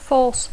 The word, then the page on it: force: English